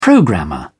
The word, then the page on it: programmer: British English